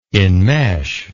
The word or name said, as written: inmesh